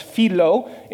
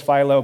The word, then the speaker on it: Philo: As Junior was reminding me, it's actually pronounced Philo in the Greek, but nonetheless, everybody says Philo because we are anglicized and domineering colonizers, apparently.